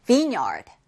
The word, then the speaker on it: vineyard: Vineyard. Vineyard. 여러분들 요 V-I-N-E. Vine이라고 원래는 읽습니다. 근데 얘가 yard랑 같이 결합하면 Vineyard가 아니라 Vineyard라고 발음이 되고요.